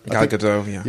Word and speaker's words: Gal Gadot: Gal Gadot? – Gal Gadot.